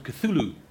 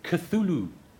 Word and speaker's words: Cthulhu: Logan's cat is called Cthulhu. – Can you say that again, please? – Cthulhu.